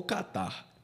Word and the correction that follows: kata: Shiai ou kata?